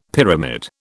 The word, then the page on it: pyramid: British English